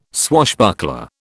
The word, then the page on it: swashbuckler: British English